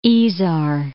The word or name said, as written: Isar